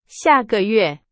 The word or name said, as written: 下个月